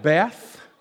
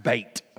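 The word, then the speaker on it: Bet: It looks like Beth, B-E-T-H. How many of you have Beth there? Well, if you have Beth there, that's not how it's pronounced. It's pronounced Beit, okay?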